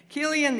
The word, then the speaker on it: Chilion: Then Chilion, and the C-H is pronounced with a K, Chilion,